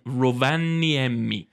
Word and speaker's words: Rovaniemi: Rovaniemi. – Pretty much got it perfect. Oh, Rovaniemi. You got really man. – Close. Rovaniemi.